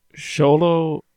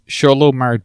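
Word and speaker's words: Xolo: Sholo Maridueña is what I understood from that pronunciation. Because X is just like a sho sound, I think, in Spanish.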